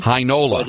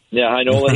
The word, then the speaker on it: Heinola: I mean, they got Ville Heinola, who is Heinola, I guess you would know how to pronounce it better. You called their game. – Heinola. – Heinola.